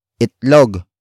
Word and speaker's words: itlog: And that is itlog.